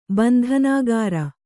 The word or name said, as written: ಬಂಧನಕಾರಿ